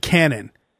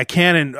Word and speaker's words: Kanan: I mean, I said, I don't care how you pronounce it, but it's spelled K-A-N-A-N. He goes, oh, Kanan. I said, it's Kanan. You can pronounce it however you want, but the spelling is K-A-N-A-N.